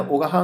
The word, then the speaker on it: 男鹿: まあ、秋田では男鹿半島という皆さんご存知のなまはげのねこの発祥の地、男の鹿とあえてこれ男鹿半島と読むんですけれども、